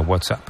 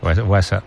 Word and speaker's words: WhatsApp: WhatsApp. No, peor aún, con acento en la primera.